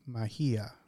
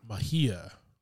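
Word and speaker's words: Mejía: Tinoch Huerta Mejia. Did I pronounce that correctly? – Probably Mejia. – Mejia. Tinoch. – J's going to be your H there.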